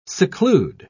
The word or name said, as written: seclude